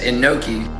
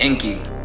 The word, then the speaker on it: Enki: And yes, Enki is mentioned in the Bible, which is Aleph, Nun, Kaf, Yod, which they will try to say that it says Enoki, but phonetically it's literally just Enki, which is Enki, Yod, He, Vav, He.